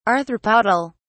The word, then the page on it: arthropodal: British English